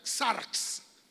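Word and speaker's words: sars (σάρξ): Esa palabra se, pronuncia sars como esa palabra sars Es hablar de una mente carnal.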